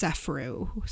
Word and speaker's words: Sefrou: and I believe it's pronounced Sefrou, so S-E-F-R-O-U.